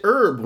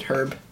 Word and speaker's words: herb: Why do we pronounce it herb when there's an H? – I don't pronounce it herb. I pronounce it herb. – What are you, British? – No, I'm Irish.